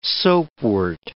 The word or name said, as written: soapwort